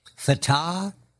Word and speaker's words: Fatah: Now, the strangest story, Fatah and Hamas in talks in China. Did I say that correctly? – You did.